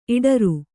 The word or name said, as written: ಇಡರು